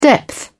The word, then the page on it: depth: British English